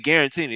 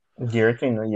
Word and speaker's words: Giratina: We had Guarantina. Yeah, we had Guarantina. – Giratina,